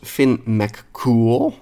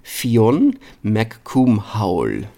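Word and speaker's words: Fionn mac Cumhaill: Vaak uitgesproken als Finn McCool. Nou, dat klinkt natuurlijk heel cool, maar het is eigenlijk Fionn McCoomhowl.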